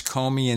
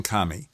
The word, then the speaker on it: Comey: And one site had both pronunciations, Comey and Comey.